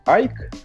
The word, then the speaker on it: AIC: называется АИСом, потому что по произношению он должен быть как Айк, потому что в конце там типа Си.